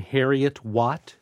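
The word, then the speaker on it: Heriot-Watt: I know I'm pronouncing this wrong. Harriet Watt. – It sounds good to me.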